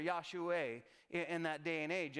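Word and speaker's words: Yahshua (ישוע): That name, Jesus, is actually in Hebrew, Yahshua, or Yahshua is the way that we would pronounce that in Hebrew.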